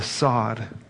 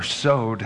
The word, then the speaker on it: sod: But the last, the sod, or sowed, actually, is how it would be pronounced, is the revelational truth of Scripture.